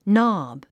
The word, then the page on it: knob: nάb; ノブ